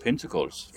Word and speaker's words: Pentacles: Pentacles yeah.